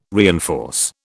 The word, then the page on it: reinforce: British English